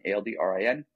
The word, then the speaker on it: Aldrin: So they can find me on Twitter at Aldrin Clement, which is just my first name and last name, as it's spelled, like Buzz Aldrin, Aldrin, Clement, like Clementine, Clement.